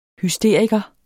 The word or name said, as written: HYSTERIKER